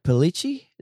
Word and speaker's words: Pellicci: The other big personnel change that I want to talk about is, correct me if I say his name wrong, but is it Derek Pellicci? Is that how you say his name? – That's it. Yeah, perfect.